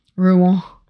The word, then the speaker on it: Rouen: How do you say it? – Probably Rouen. – Rouen. How's that? – Yeah, perfect.